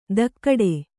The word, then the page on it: ದಕ್ಕಡೆ: dakkaḍe